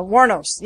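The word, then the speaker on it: Wuornos: Warnos.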